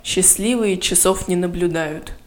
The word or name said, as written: Счастливые часов не наблюдают